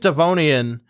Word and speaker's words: Devonian: The second one was 365 million years ago, called the Late Dovinian. I feel embarrassed because I was literally pronouncing these all correct last night. Now I'm not entirely sure. But it's the Late Devonian extinction, and there are two theories about it.